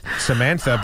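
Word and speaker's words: Psamantha: Samantha, but with a P on the front. – No. – A silent P, like in psychology or pterodactyl.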